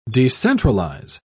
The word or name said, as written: decentralize